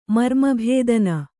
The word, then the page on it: ಮರ್ಮಭೇದನ: marma bhēdana